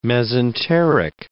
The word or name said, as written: mesenteric